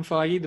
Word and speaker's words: Feige: Yeah, Kevin Feige, the big man.